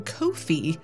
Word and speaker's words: Ko-fi: You can also find us on Ko-fi, pronounced like coffee.